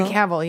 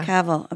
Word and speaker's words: Cavill: He's a comedian, the working man's Henry Cavill. Am I saying that right? – Henry Cavill, yeah. – Cavill, okay.